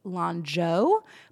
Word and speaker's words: Lanzhou: I believe the proper pronunciation is actually Lanzhou, but it's L A N Z H O U.